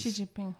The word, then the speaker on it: Xi Jinping: Was before, because from what I understand, Xi Jinping is Xi Jinping, yeah.